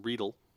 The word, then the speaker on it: Riedel: And from that day forward, I made sure that I had glassware, not specific, because I know Riedel makes a Syrah glass and a Cab glass. – It's pronounced Riedel.